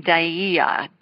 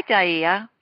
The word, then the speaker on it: Dyea: And this one comes from, I believe she pronounces her name Dyea, D-Y-E-A.